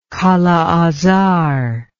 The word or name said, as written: kala azar